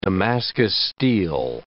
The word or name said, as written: Damascus steel